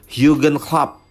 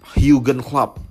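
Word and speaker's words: Jürgen Klopp: kalau bahasa Jerman tuh kan bacanya beda ya kayak Schalke 04 itu klubnya itu dibaca Schalke Novie. Kalau Jurgen Klopp ini bacanya Jurgen Klopp, Jurgen Klopp.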